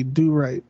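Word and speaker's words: Dew Rite: Yeah, the first name is Dew, D-E-W. And the last name is spelled R-I-T-E, do right.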